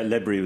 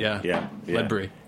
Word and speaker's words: Ledbury: How's that pronunciation? – Yeah, Ledbury was Ledbury, very good pronunciation. – Ledbury. – Yeah, Ledbury. – These guys, they're working on their... – not too heavy on the lead.